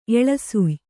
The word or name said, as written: ಎಳಸುಯ್